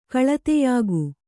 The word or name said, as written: ಕಳತೆಯಾಗು